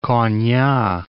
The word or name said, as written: Konya